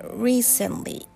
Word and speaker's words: recently: While listening to an audiobook, it sounds like a dropping T sound in the word. I realized that I pronounced the T sound quite strongly. えそこから、え recently